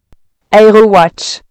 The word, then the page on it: aerowatch: prononciation française